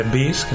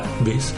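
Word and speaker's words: B'z: De rock así tipo Aerosmith, te diría probablemente Beast, que se escribe B-Z, Beast, o te diría Dark Ciel o Glay eh, tipo indie, te diría home te diría, yo que sé, un montón de grupos.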